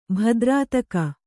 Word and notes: ಭದ್ರಾತಕ: Kannada

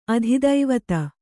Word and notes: ಅಧಿದೇವತೆ: adhidēvate

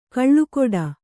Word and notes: ಕಳುಗುಡಿ: kaḷuguḍi

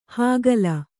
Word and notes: ಹಕ್ಕಲ: Kannada

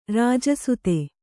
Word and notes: ರಾಜಸುತ: rāja suta

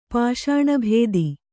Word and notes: ಪಾಷಾಣಭೇದಿ: Kannada

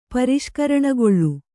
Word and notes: ಪರಿಷ್ಕರಣಗೊಳ್ಳು: pariṣkaraṇagoḷḷu

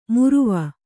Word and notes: ಮುರುವ: muruva

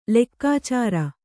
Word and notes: ಲೆಕ್ಕಾಚಾರ: lekkācāra

